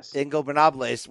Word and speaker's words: Ingobernables: Ignobernables. Man, I always butcher that. – Ingobernables, which is obviously a shout out to Andrade, her real life boyfriend, apparently right now, who started that faction in Mexico.